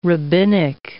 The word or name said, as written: rabbinic